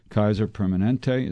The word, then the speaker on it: Kaiser Permanente: We're talking to the good folks from Kaiser Permanente. Is that the way you pronounce it? – Okay. Yes, that's correct.